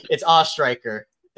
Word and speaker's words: Ostriker: Know, some people just say ostrich and give up. So I appreciate you letting me take the reins here. It's Ostriker. It's like the American Pie thing.